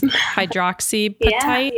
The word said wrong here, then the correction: hydroxyapatite: Yeah, so hydroxyapatite is actually an organic mineral that is in our tooth structure.